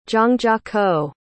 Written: zhangjiakou

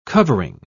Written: covering

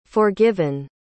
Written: forgiven

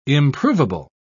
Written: improvable

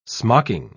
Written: smocking

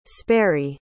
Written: sperry

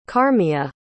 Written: carmiya

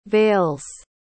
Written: veils

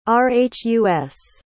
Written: rhus

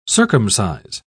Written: circumcise

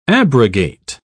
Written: abrogate

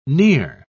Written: near

